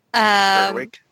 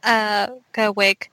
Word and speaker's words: Gerwig: Whereas, Jordan Peele for Get Out and Greta, sorry, I always say her name wrong. – Gerwig. – Yeah, Gerwig. I always want to say Wing.